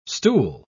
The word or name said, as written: stool